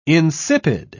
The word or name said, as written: insipid